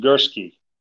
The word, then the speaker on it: Gursky: It's pronounced Gursky. – Gursky, okay. – There's an R sound there, even though there's no R in the name.